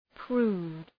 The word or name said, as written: prude